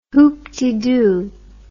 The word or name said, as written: hoopty do